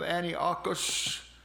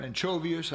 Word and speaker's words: Antiochus: The Syrian ruler by the name of Antiochus, or Anchovius, I've heard it pronounced both ways,